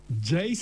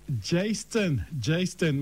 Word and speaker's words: Jaistone: Jaistone. – So like Jason and Justin together. – Jaistone, my apologies.